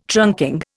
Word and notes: trunking: Indian

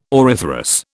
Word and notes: auriferous: British English

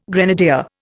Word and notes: grenadier: Indian

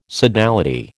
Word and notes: sodality: American English

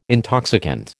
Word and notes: intoxicant: American English

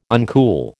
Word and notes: uncool: American English

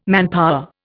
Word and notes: manpower: Indian

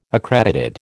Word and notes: accredited: American English

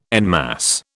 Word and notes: en masse: British English

